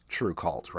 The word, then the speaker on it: TRVE KVLT: And just to clarify for anybody listening who wasn't watching earlier, it's spelled TRVEKVLT, but pronounced True Cult, right?